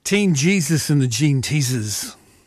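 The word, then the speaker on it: Teen Jesus and the Jean Teasers: When we come back from this quick break, we will have Canberra band Teen Jesus and the Gene Teasers. Yes, I said it right.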